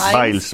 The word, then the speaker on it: Biles: se llama Simón Biels y... ¿Cómo se pronuncia? Biles. Biles.